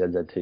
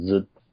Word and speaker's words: ZZT: And then books like Anna Anthropy's Zut, because it's not pronounced ZZT, it's meant to be Zut, is like, I've never played that game.